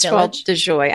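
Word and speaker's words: Toile de Jouy: Well, it is Toile de Joie. I don't do the French pronunciation, but that's right.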